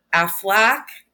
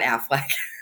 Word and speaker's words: Afallach: And then the last daughter, Aflac, it's not Aflac. – It's Aflac. That's what they named it after. That's crazy. – It's spelled A-F-A-L-L-A-C-H. So I know there's a kah at the end, but like, he is thought to be the father of the goddess Modron, who was the inspiration for Morgan Le Fay.